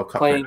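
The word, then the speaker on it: Cochrane: Zefram Cocaine. – Oh, Cochran. Playing Zefram Cochran.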